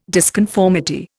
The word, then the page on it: disconformity: Indian